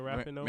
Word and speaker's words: Rapinoe: Just because, in terms of ladies, you know, I gotta go with I always mispronounce her name, Megan Rapinoe. Is that how you say it? – Megan Rapinoe.